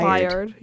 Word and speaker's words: Byard: Ryan Bates and Kevin, I want to say his name's Byard. – Byard, yeah.